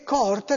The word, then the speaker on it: coorte: A proposito, la questione della corte o coorte è vera. Anch'io ho controllato, c'è l'accento grave sulla seconda O. Quindi stringiamci a coorte. Perché coorte deve far rima con morte. Se no, sarebbe morte. Coorte.